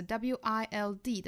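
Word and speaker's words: Wilde: 后面 wild 这个单词和这个野生的这个形容词 wild 的发音是一样的，这个单词要注意它的双元音发音，从第一个元音 a 过渡到第二个元音 a， a 到 a， i 再加舌侧音 wild 最后再加前面的辅音 wild 就这么出来了。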